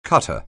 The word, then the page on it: cutter: anglais